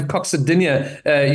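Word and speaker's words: coccydynia: Are you able to help with a condition called, and I don't know if I'm going to even pronounce it right, coccidia? Coccidia? – There we go.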